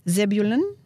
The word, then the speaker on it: Zebulun: For those who were in distress in the past, he humbled the land of the, Zebulun.